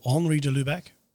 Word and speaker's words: Henri de Lubac: It is a book written by Henry de Lubac, or Henri de Lubac, insist on the correct pronunciation of his name, The Drama of Atheist Humanity.